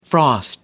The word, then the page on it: frost: American English